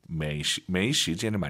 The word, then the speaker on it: 没时间: 당연히 没时间을 많이 쓰겠죠. 저 지금 발음 틀릴 뻔했어요, 여러분. 没时间, 没时间 다 알아들어요. 틀린 발음이에요. 没 아니죠? – 没 왜 올라가야 돼요? 아아 没时, 没时间 이성이 길어야 예뻐요.